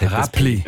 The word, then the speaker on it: Räppli: Du hast es mit einem B ausgesprochen. Und Räppli. – Ja, Räppli.